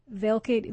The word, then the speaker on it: Velcade: Velcade, V-E-L-C-A-D-E.